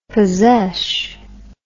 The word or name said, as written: possesh